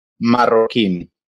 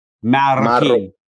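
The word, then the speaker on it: Marroquín: And then in your Sebastian surname, how do you pronounce that? – Marroquin. – Marroquin. – Marroquin, yes.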